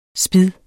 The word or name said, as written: spid